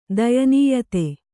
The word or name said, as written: ದಯನೀಯತೆ